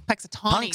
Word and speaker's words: Punxsutawney: How do you pronounce that? – Punxsutawney. – Punxsutawney. – Punxsutawney, yeah.